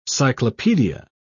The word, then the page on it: cyclopaedia: British English